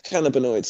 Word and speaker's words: cannabinoids: It is cannabinoids.